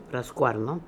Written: Rascooarno

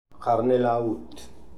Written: Harnel Aoot

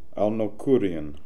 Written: Alnokhoorian

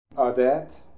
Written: Adiat

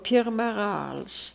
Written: Pirmarals

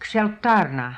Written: Ksheltarna

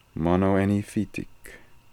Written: monoeniphitic

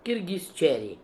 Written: Kirghizcheri

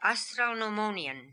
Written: Astralnomonian

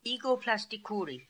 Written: Egoplastikoori

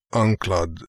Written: Anklad

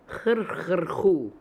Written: Hrkh hr hoo

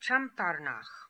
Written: Champarnakh

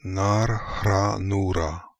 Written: Nar Khra Noora

